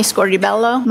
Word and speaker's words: Scordibello: My name is Irini Scordibello. My last name is spelled S-C-O-R-D-I-B-E-L-L-O.